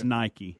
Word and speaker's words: Nike: They cleared that up many years ago. Right. It's Nike. – Well, it is spelled Nike, though. – It is, but that's not how they say it.